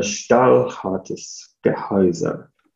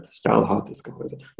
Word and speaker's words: Stahlhartes Gehäuse: um, and the concept of, I'm trying to pronounce this in German because my wife speaks German, she told me how to pronounce it. Um, uh, Stahlhartes Gehäuse, right? Stahlhartes Gehäuse.